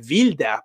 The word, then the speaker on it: Wilder: Non dimentichiamoci che Billy Wilder, noi lo conosciamo come Billy Wilder, ma in realtà, per essere giusti, dovremmo pronunciare il suo cognome Wilder,